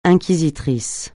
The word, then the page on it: inquisiteur: francais